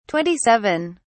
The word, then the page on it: 27: British English